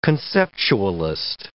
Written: conceptualist

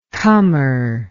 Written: cummer

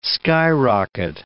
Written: skyrocket

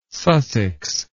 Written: Sussex

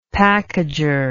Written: packager

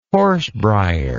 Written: horsebrier

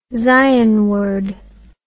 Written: zionward

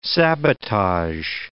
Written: sabotage

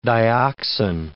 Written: dioxin

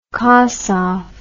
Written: kossoff

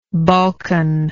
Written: balkanite

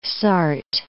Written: Sarthe

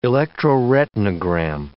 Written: electroretinogram

